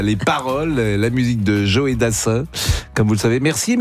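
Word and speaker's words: Joe Dassin: Je ne connaissais pas cette manière de prononcer Joé Dassin. – C'est Joé Dassin.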